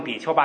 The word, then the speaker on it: 比丘: 就你就读成比丘吧，因为我们现在流行的是比丘，